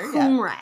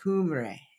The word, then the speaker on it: Cymraeg: I believe you say it, it's spelled C-Y-M. R-A-E-G. And the C is always a hard C. – We're going to get to that later. – Cymraeg. – Yeah. Cymraeg.